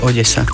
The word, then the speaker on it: Odesa: V slovenčine väčšinou D a E sa vyslovuje meko, de. A keď sa to má vysloviť tvrdo, tak je naznačená tvrdá výslovnosť. Takže ak tam nie je nič hra na tej zátvorke, znamená to, že sa vyslovuje Odesa.